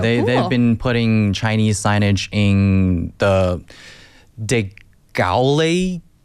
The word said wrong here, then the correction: De Gaulle: De Gaulle, but, maybe? Charles yeah. – De Gaulle. Okay. – Charles de Gaulle.